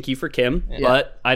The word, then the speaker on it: CHIM: I pronounced them Kimmer earlier because I looked up the pronunciation for Chim, all caps, and one of the devs came out in a forum post and said it's pronounced Kim.